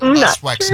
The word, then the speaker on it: Wexner: no, seriously, he was Epstein's Lex West... What? How do you say it? – Lex West. – Lex Wexner.